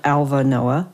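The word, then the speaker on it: Alva Noë: What I love about this is I was going to ask you to share that quote from Alva Noah, and I even listened on pronunciation to see if I was saying it right, and it is.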